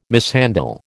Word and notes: mishandle: American English

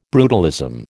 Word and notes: brutalism: American English

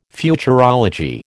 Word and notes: Futurology: American English

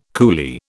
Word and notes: coolie: British English

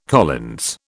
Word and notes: Collins: British English

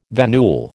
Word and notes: venule: American English

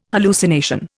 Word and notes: hallucination: Indian

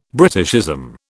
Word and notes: Britishism: British English

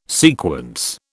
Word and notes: sequence: British English

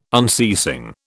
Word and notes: unceasing: British English

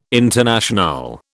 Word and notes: Internationale: British English

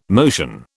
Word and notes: motion: British English

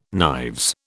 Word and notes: knives: British English

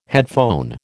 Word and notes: headphone: American English

